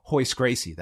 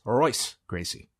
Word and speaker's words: Royce Gracie: But I mean, at the same time, it's you're considered an idiot if you call Hoist Gracie that. Yes. And yet I've been told it's Royce Gracie. – That is right. the accurate pronunciation. Hoist is not a thing.